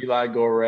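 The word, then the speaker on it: Eli Goree: Is it Eli Gore and Kingsley Benadir.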